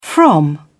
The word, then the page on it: from: British English